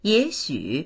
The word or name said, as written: ye3xu3